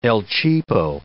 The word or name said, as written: el cheapo